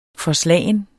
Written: forslagen

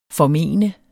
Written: formene

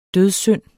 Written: dødssynd

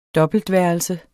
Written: dobbeltværelse

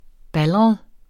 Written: baldret

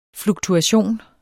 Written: fluktuation

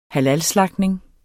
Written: halalslagtning

